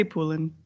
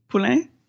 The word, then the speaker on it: Poulin: I say Poulin. – Okay. – Just because most people don't know the French way of saying it, so they go, huh? – And how should it be pronounced?